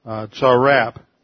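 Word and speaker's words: Tzarap (צרף): The Hebrew word sarap, which is, you could spell it T-S-A-R-A-P. Sarap. – Tzarap.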